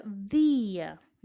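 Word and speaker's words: día: Por exemplo, a palavra dia, dia em português, sempre se pronuncia dia. Uhum. Esse chiado que tem em português nessa palavra, um, no, não sei se é no português mais paulistano, mais carioca, mais aqui do sudeste, mas enfim, a gente não tem em espanhol, tá? Palavra dado ou dia tem o mesmo som.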